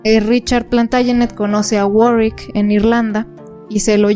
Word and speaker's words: Warwick: Bueno, se escribe Warwick porque era el conde de Warwick, pero en realidad se pronuncia Warwick.